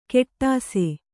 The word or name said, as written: ಕೆಟ್ಟಾಸೆ